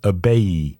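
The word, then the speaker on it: Ibeyi: known as Abeille. That's spelled I-B-E-Y.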